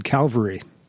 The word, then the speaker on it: Calvary: Notice I said Calvary and used it correctly. Along with Rogue, this is the other word, I guess, that isn't misspelled often, but mispronounced often. So Cavalry is the guys that come riding in on the horses. Calvary is the mountain that Jesus was crucified on.